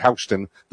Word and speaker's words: Houston: but he pronounced it Houston the Scottish way.